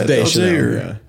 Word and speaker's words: Deschanel: Is her name pronounced that? – No, it's Deschanel.